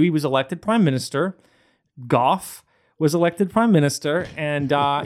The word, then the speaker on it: Gough: Is it Gough Whitlam? Is that how you say it? – That's Gough. – Gough. Really? Mm-hmm. Gough. – Am I wrong, Andrew? – No, it's Gough Whitlam.